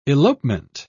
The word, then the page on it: elopement: British English